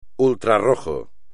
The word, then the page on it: ultrarrojo: espagnol